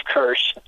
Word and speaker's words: Kersh: My Facebook page, and this is a long title, and I apologize, Storm Cert 7 Chief Meteorologist Steve Kirsch. And the last name is spelled K-E-R-S-H.